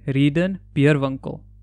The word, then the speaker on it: Readon Beerwinkel: Readon Beerwinkel.